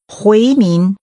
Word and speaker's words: 回民: 回民。民。回。回民。回民。 – Pronounced with two rising tones.